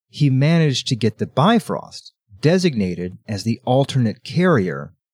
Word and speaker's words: Bifrost: Okay, for listeners, listen, Bifrost is a perfectly accepted way of pronouncing it. But in Old Norse, it is something along the lines of Bifrost.